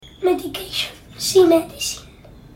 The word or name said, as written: medication